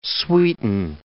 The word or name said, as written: sweeten